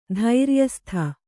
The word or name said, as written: ಧೈರ್ಯಸ್ಥ